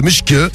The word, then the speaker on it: Majk: Et donc, le maître Gims albanais s'appelle Mushk. Ça s'écrit M-A-J-K. Et on va vous mettre la vidéo de Mushk.